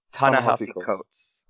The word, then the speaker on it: Ta-Nehisi Coates: Can you help me pronounce this? Kote? – Ta-Nehisi Coates. I'm sorry. It's one of those names I've never heard out loud until said it to me once. So Ta-Nehisi Coates.